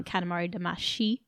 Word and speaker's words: Katamari Damacy: I'd like to introduce to you the hope that you might take interest in a game called Katamari Damacy, or my Japanese is terrible, but the original intended pronunciation, Katamari Damacy, and it is a PS2 release It's probably reaching its like 15-year mark at this point, I think.